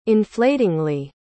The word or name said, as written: inflatingly